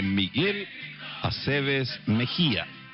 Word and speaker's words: Miguel Aceves Mejía: Hoy vamos a hablar de la vida y las canciones de Miguel Aceves Mejía. No confundir, Mejías no, ni Aceves tampoco, menos a veces. Miguel Aceves Mejía, con acento en la E.